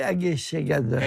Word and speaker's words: Egészségedre: In Hungarian, it would be Egészségedre.